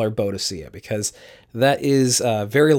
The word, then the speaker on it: Boadicea: And uh, there's a couple different ways of pronouncing it. I'll say in the traditional kind of British way first, and that is Boadicea.